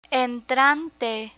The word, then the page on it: entrante: Spanish